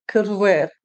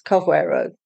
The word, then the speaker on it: Carvoeiro: As a Portuguese would say it, it would sound more like Carvoeiro, as they cut off the final part of a lot of the words, especially if it's a vowel sound. Most Westerners would pronounce it as Carvoeiro.